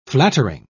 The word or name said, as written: flattering